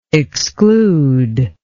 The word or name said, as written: exclusory